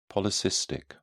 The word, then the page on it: polycystic: British English